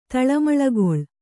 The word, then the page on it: ತಳಮಳಗೊಳ್: taḷamaḷagoḷ